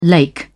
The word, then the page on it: lake: British English